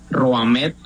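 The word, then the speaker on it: Rohameth: Si la memoria no me falla, Rohamed, así como se escribe mi nombre, Rohamed, con TH lo último, es el único nombre en Colombia y me atrevo a decir que en el mundo.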